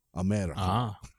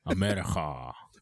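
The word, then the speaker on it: Amerika: There is a 2.5-hour documentary about the band Rammstein on Amazon right now, and it's called America with a K, so you gotta say it like you're German. America. – America.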